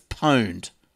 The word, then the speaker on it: pwned: Just a correction on pawned. It's a new word for owned and pronounced the same, just like pawn to own. But so anyway, I think we were talking about this the other week. So it's pwned. That's how you pronounce it. Owned, as in owned.